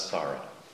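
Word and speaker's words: sāra: And actually, the word is Sara in Pali, S-A-R-A, which is kind of like the English word Sarah, but it's pronounced Sara.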